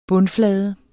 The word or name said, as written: bundflade